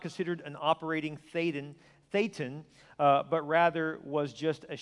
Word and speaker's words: Thetan: In the theology of Scientology, every human being in his true identity is a Thetan, pronounced Thetan. I realize what that rhymes with.